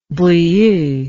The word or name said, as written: brieux